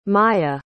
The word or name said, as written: mya